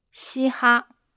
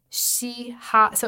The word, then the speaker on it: 嘻哈: How do you say it? – 嘻哈 Okay, 嘻哈 So 两个第一声是吧 Okay, hip-hop And I guess that's like a transliteration, right?